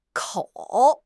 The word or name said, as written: kou3